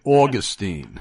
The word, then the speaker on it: Augustine: Yeah, only a New York former Catholic would say Augustine. – Augustine, sorry. – Well, I know both are apparently acceptable pronunciations.